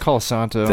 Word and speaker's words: Colasanto: Nicholas Costanzo, or no, I didn't say that right, did I? – No, it's Colasanto.